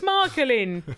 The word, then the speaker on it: Michelin: Michelin, isn't it? – It's Michael in. – Is Michael in? That is how I say it.